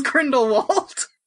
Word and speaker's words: Grindelwald: Grindelwald. Suck my dick. It's Grindelwald. – Yeah, I haven't been pronouncing Voldemort correctly, so might as well just go. – Fuck off. – It's French. Okay, whatever.